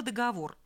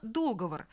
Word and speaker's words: договор: В качестве примера слово «договор». Это строго литературная норма. В словаре 59 года допускается разговорная форма «договор».